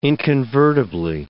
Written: inconvertibly

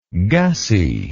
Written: gussie

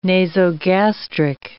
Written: nasogastric

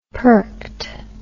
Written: perked

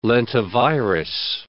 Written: lentivirus

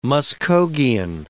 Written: Muskogean

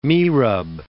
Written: mihrab

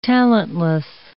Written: talentless

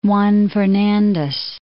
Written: Juan Fernandez

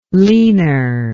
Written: leaner

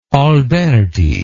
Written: alberti

